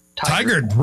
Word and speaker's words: Tigard: And I always mistake how to pronounce this town in Oregon. Tigard? – Tigard, right next door to me.